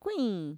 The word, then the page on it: kuiin: Mixtec